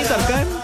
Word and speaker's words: Tarkan: Aquí está Tarkan, se llama. Bueno, no sé cómo se pronuncia, ¿verdad? Pero. – Sí, Tarkan.